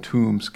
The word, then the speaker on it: Toombs: So Santa Claus is located in Toombs County. That's T-O-O-M-B-S, not T-O-M-B-S, although okay. – It's pronounced the same.